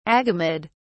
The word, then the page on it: agamid: British English